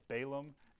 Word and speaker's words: Balaam: I just learned how to pronounce both those names, by the way. Google's very helpful. Balaam and Balak. Been pronouncing them wrong my whole life.